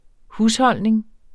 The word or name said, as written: husholdning